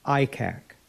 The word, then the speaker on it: ICIAC: or ICIAC, pronounced ICAC.